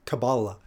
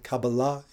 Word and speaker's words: Kabbalah: And Kabbalah is the Israeli or, you know, more traditional pronunciation, but Kabbalah is the, you know, sort of Anglo-American pronunciation. Nothing wrong with them. It's simply tomato, tomato. But either way,